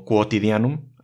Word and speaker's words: quotidianum: La Q seguida de una U se pronuncia como Q. En latín la U nunca es muda, como a veces pasa en español. Entonces al leer una palabra como quotidianum, así es como se lee.